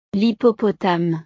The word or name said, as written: l'hippopotame